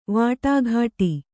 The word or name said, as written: ವಟಗುಟ್ಟು